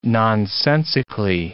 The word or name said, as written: nonsensically